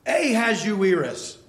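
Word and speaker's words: Ahasuerus: And they would all in unison scream out at the top of their lungs, "Ahaz Uirus."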